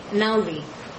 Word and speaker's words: Naudy: Naudy.